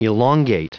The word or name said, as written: elongate